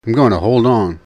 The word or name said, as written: I'm gonna hold on